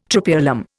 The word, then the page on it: Tropaeolum: Indian